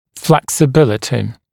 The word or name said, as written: flexibility